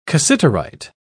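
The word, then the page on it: castanets: British English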